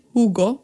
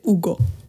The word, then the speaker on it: Hugo: Nej, så man får inte säga Hugo, man ska säga Ugo.